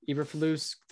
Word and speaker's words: Eberflus: The Bears have hired Matt Eberflus. Is that how you say it? The last Eberflus, name? – Yeah. Eberflus, former Colts defensive coordinator.